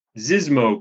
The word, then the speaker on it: Xizmo: There's another case, the Zizmo case, I think is how you pronounce it, X-I-Z-M-O in New York,